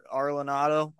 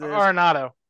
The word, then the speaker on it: Arenado: We talked about that off air, but Nolan Ar- Arlenado, I think it is. – Arenado.